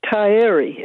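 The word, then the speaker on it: Taieri: Which is Tyree. Is that right? – Ah, yes.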